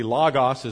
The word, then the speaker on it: logos: And sometimes I think we pronounce it different ways. It's the word logos. Probably logos is the better way, but with logos, computer, everyone kind of gets that.